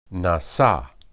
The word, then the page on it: nasa: Hebrew